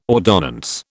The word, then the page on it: ordonnance: British English